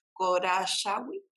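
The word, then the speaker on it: Khosrowshahi: Yo estuve leyendo una, una reciente entrevista que le hicieron al CEO, Dara, a ver si lo pronuncio bien, Korashawi. sí, está perfecto.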